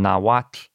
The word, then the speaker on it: Nahuatl: But the hard part is spelled starting with a TL, and it's based on Nahuatl, which is as best I can pronounce the language that we usually pronounce, Nahuatl. – So we've been doing it wrong. – Yes. So it's really just two syllables, and it ends in that sort of soft T-sounding thing. Really what it is it ends in what's called a lateral fricative, which isn't in a lot of widely spoken languages.